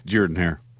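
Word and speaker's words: Jordan-Hare: It is Jordan Hare. – Really? – Okay. Both Alabama and Auburn Stadiums made this list. They were 8 and 10, by the way. Bryant Denny was 8th, and Jordan Hare Stadium was 10th.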